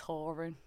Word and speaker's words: Horan: Because James Corden asked Niall and he said it's Horan.